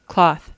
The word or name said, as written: cloth